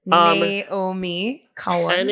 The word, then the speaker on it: Naomi Cowan: Naomi Cohen. – N-A-O-M-I-C-O-W-A-N.